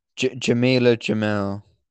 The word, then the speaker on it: Jamila Jamil: Jamila Jamil. – Jamila Jamil? – I assume that's how you pronounce her name.